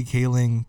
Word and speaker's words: Kaling: Kaling. Why did I say Kaling? I was saying her name right the entire time.